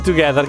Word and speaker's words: 2G3TH4: Si chiama Together, ma scritto 2G3TH4. Vabbè. Si legge Together, grazie a Riccio che è più lungimirante di noi.